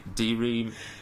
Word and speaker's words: De Re: It's De, oh, uh, De Ra Militari. De, de Re.